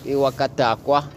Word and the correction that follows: Wakajakwa: Did I say that right? – Yeah, Wakajakwa.